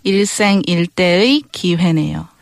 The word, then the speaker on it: 일생일대의 기회네요: 일생일대의 기회네요. – Okay. 일생일대의 기회네요. – Right. 일생일대의 기회네요. – And I imagine when people say it fast, that 일대의 kind of blends into one syllable, kind of blend into one syllable, yes.